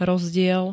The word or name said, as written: rozdiel